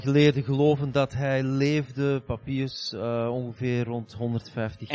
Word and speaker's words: Papias: And he quotes a man, his name is hard to pronounce, it's Papias.